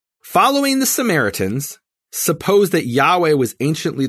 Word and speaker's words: Yahweh: And Rayland in 1707, he talked about how the Samaritans preserved the true pronunciation of Yahweh, of the name Yud-Heh-Vav-Heh.